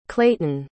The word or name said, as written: clayton